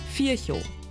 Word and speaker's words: Virchow: Laut Duden spricht sich Virchow mit stimmlosem F vorne und mit stummem W hinten. Virchow.